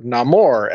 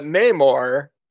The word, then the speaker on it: Namor: So let's talk about Namor, or Namor, as he calls himself, and then everyone else doesn't respect his pronunciation as a name.